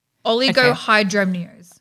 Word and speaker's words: oligohydramnios: Okay. Oligohydraminos. – Nope. – Oligohydraminos. – No, I don't know it. – Yeah, I just had to say it again. Oh, I feel like I didn't say it right. Dremnios. Oligohydramnios. – Okay. O-L-G-I-O. – Well, this is a sentence word. – Oh. How do I say it? – Oligohydramnios. Oligohydramnios.